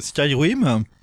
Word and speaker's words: Skyrim: En fait, ça vient de, du jeu euh, Skyrim, et euh, donc elle reprend euh, des, des, Hervé, oui.